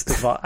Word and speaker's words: DeVos: It's DeVos. I think it's DeVos. I think the S is pronounced.